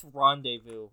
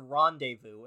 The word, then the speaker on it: rendezvous: V-O-U-S. Rendezvous. Vows. Rendezvous. Rendezvous. You're pronouncing R, Ron, D, E, V, O, U. You don't need all that.